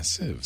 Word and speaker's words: sieve: Yes, you can pronounce it both ways. How do you say it, Leo? Is it sieve or sieve? – Yeah, sieve. – Sieve. Okay, sieve.